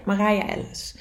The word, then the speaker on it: Marije Ellis: Volgens mij heet ze Marije Ellis, dacht ik. Zeg ik dat goed? Ja, Marije Ellis.